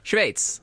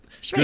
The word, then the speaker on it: Schweiz: Kan vi uttala landet om vi börjar där? – Schweiz. – Japp. Yep. Schweiz. Gustav Persson, Schweiz, möter Sverige klockan 20:20 ikväll.